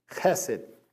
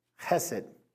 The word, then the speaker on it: chesed: And there's one amazing theme in the passage of Hosea is this, the word chesed. Chesed. You pronounce a ch like a ch, like if something was on your throat. Chesed.